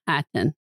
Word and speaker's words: Mulhattan: Mulhattan. Like Mulholland.